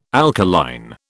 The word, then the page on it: alkaline: British English